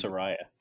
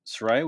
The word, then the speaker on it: Saraya: I never remember how to pronounce it. – I know that she said, like, it's pronounced this way. I think it's Saraya.